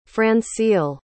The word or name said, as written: francille